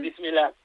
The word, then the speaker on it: Bismillah: Una cosa que me dijo Ilia Sakomaz, a ver si la pronuncio bien, Bismillah. – Eso es. – ¿Lo he dicho bien? – Bismillah. Sí, muy bien, muy bien.